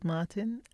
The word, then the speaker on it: Martin: My name is Charlotte Yearwood Martin,